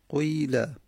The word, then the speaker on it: قیل: سوم اشمامه که همون قیله، قیله اشمام هم به اون فعل فعل که قاف باشه، در اینجا قیله فعل فعل قاف باشه، مخلوطی از دمه و کسره میدیم، دمه رو تلفظ میزنیم، فورا میریم به کسره. خب یه مقداری سخته، قویله قویله.